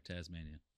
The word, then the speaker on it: Tasman: Tasmanian Sea. Is it Tasman or Tasmania? – Tasman. – God damn it. – It's spelled Tasman.